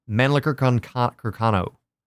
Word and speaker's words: Mannlicher Carcano: Mannlicher Carcano. That's how they say it in all the documentaries anyway. That's the only way. That's the only reason I know how to say it.